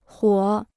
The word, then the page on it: 火: huǒ